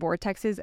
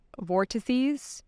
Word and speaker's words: vortices: And I used to call them vortexes. Apparently it's vortices is the correct like plural pronunciation.